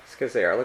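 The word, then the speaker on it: Scazzero: The first of 8 weeks going through a book by Peter Scazzera. Am I pronouncing that right? – I believe you are. – Scazzera.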